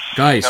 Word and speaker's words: Guice: I saw that they had that Derrius, I don't know how you say his name, Guice? Derrius Guice. Guice. The running back.